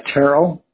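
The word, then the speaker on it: Tyrrell: Terrell, and it's spelled T as in Tom, Y-R-R-E-L-L.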